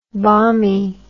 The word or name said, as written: bommie